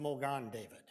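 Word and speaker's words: Magen David: In Hebrew, it's pronounced Mogan David, is what they refer to it as.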